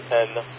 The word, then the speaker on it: Tenergy: TENERGY, Tenergy? Is that how you pronounce it? – Yeah, you got it there, buddy. Roger. I just saw a YouTube video on it, and he said it the way you said it.